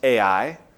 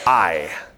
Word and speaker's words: Ai: If you grew up like me, you heard that pronounced Ai. It is a single syllable, Ai. If you want to call it the other thing, that's just fine too.